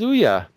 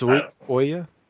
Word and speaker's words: Ouya: I thought it was Ouya, because the email said something like it's pronounced like Booya. But it's Ouya?